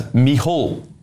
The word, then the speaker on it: Micheál: I'm sore from laughing here at your pronunciation of Micheál Martin, which is what I've been saying so far today. She says, it's actually pronounced as Micheál, the Irish for Michael.